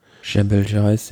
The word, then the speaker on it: Jebel Jais: Wie spricht man das aus? Jebel Jais? – Jebel Jais, ja.